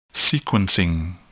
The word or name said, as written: sequencing